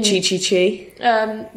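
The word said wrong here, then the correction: chichi: Do you mean shishi?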